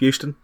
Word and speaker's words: Euston: Euston? – Yep, Euston.